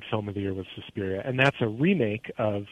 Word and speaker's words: Suspiria: Suspiria, that's right.